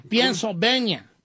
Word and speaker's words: Pennsylvania: Fíjate cómo se dice. No sé si Pensilvania, sí, sí, Pennsylvania, Pennsylvania, Pennsylvania, así se dice.